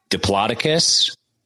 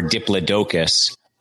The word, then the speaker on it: Diplodocus: The most popular way to say it, supposedly, is Diplodocus or Diplodocus, but all 3 are ways to pronounce it.